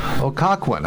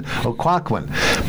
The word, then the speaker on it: Occoquan: I didn't have time for what they call a spook spy tour, but I did visit an area filled with ghosts, and that's called Occoquan. I think I'm pronouncing it correctly. – I think that's right. We have heard Occoquan? – About it. – Yeah, it's a strange spelling. O-C-C-O-Q-U-A-N. Occoquan. But after a nice dinner and a few drinks, the people took me out at night.